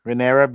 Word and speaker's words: Rhaenyra: Despite Princess Reyes, what is it? – Rhaenyra being the rightful heir, Queen Go.